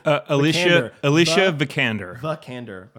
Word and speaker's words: Alicia Vikander: The MVP in my mind for this movie was, like I said, Alicia Vikander. Did I say it right again? – Alicia Vikander.